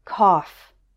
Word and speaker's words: cough: O-U-G-H can be pronounced like uff in stuff, as in these words. Enough, tough, rough. There's one word where it's pronounced off, and that is the word cough.